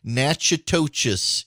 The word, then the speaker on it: Natchitoches: It is N A T C H I T O C H E S. Natchitoches is what you will probably pronounce it as. It's actually called Natchitoches.